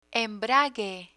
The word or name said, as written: embrague